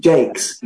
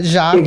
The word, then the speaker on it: Jacques: Brian Jakes? – I would call it Jacques. – Jacques, exactly. But he's a Northern Englishman, firmly going to pronounce his name English.